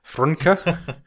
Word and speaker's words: Frnka: There's no vowels in it. Frunka. Frunka, F-R-N-K-A, who plays a character called Lexi.